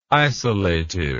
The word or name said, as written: isolative